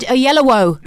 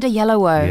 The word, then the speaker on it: Oyelowo: Yolo. – David Oyelowo. – Ah. – Oh, thank Christ. – Yes. – David Oyelowo. – Yes. – David Oyelowo.